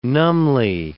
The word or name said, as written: numbly